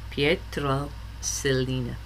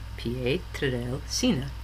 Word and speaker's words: Pietrelcina: I always say it wrong. Pietrelcina. Pietrelcina. There we go.